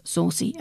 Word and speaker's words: Saoirse: It was remarkable working with Saoirse, which is how you pronounce saucy, apparently, Loden said, while promoting the film during a December 2018 interview for the All Build series.